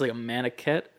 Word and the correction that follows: maquette: Maquette, I think is the pronunciation.